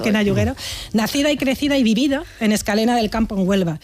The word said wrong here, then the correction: Escacena: Escacena. Mira que te lo he dicho mal varias veces, ¿eh? Por ahí está, Escacena.